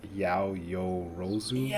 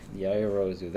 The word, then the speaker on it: Yaoyorozu: It's like Yaoyorozu. – Yaoyorozu.